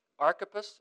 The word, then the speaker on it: Archippus: He gets to the end of this list, and he has a message for Archippus. Am I saying that right? Yeah, I just want to check.